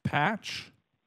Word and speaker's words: Patch: My name is Patch. – Could you please spell that for me? – Sure. It is P-A-T-C-H.